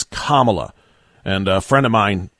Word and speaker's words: Kamala: Remember, it's Kamala like communist, not Kamala like capitalist.